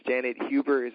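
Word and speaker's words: Janet Hubert: I think her name is Janet Huber. Did I say that correctly? – That sounds right. Yes.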